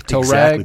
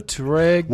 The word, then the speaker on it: Touareg: And the Atlas arrives, and the Touareg goes away. Touareg. – Exactly. The Touareg.